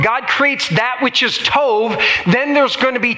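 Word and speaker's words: tov (טוב): T-O, like we would say T-O-B, so it looks like tob. But it's pronounced tov.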